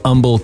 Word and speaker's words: Humble: It's got an H, but everyone says Humble, Texas. – Yep, Humble. – The H is silent.